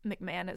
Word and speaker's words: McMahon: I think McMahon is how you pronounce her last name.